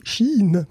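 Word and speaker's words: Shein: Ouais, il y en a un autre qui va être sérieusement inquiété par cette loi, c'est Chine. Remarquez. – Ah, dis donc, c'est fou, tu le prononces vachement bien.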